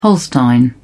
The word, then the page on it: holstein: British English